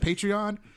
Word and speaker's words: Patreon: Is it Patreon? – Yeah.